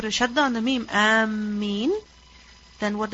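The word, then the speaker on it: Ameen (آمين): Alif has a mad on it. Meem has no shadda. And after the meem is a ya. Ameen. So it's necessary to pronounce it correctly. Just as we pronounce the words of Surah Al-Fatiha correctly, so Ameen must also be pronounced correctly.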